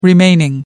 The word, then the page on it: remaining: American English